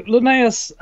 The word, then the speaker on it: Linnaeus: Carl Linnaeus? – Linnaeus, yes. – So what was his contribution to all of this? Particularly, and by all of this, I mean the term mammal. – Right. So Linnaeus, he was a pretty confident dude.